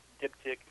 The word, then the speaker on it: diptych: This poem is actually intended to be, I think, now, you correct me if I'm wrong, it's diptych. I don't know if that's how people pronounce it, diptych, triptych, et cetera, but yes. – I'm pronouncing it. Yeah, all right, good, thank you.